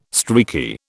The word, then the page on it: streaky: British English